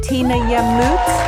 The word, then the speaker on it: Tina Yamout: Tina Yamout.